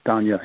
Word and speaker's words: Donja: I have Donji. Did I say her name right? – Danya. Yeah, D-O-N-J-A. Yep.